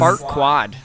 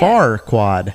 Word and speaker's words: Farquaad: How do you say Far-quad. – It? – Farquaad. – Well, it's supposed to be Far-quad? A play on words. – Yeah, it's supposed to be a play on words.